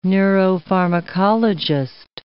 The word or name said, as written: neuropharmacologist